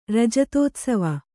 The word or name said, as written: ರಜತೋತ್ಸವ